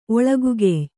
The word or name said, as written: ಒಳಗುಗೆಯ್